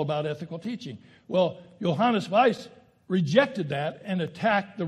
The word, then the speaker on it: Weiss: It's German, W-E-I-S-S, but you pronounce it Weiss, I'm told.